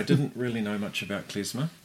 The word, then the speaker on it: klezmer: Going back to the music, another project that you've had is the Kugels, who play the klezmer. Is that how you pronounce it? – Klezmer, it is indeed, yeah.